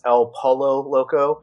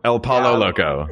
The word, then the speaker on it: El Pollo Loco: First of all, you're mispronouncing it. It's El Pollo Loco. – El Pollo Loco. – Yes. – Really? – Yeah. – Yeah, it's like the game you play in the pool, Marco Pollo.